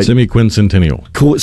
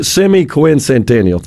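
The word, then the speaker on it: semiquincentennial: Is that semi-quintessential? I don't think I said that right. – Semi-quincentennial. – Semi-quincentennial. Thanks for correcting me there.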